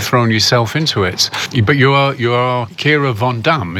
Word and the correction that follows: Van Damme: So the correct Dutch way to pronounce it would be Kira Van Damme, which sounds a bit different.